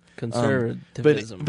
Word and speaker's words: conservatism: So we talked about what we also, before we kind of use that to talk about, you know, how social conservatism. I can't say that word. – Social conservativism. – Okay. There's an extra syllable in there. I just don't want to pronounce. – Conservatism.